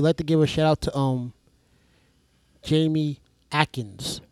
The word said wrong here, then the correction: Akins: Akins. – God, I still can't pronounce Akins. – Big Bluto. – Akins. Jamie Akins.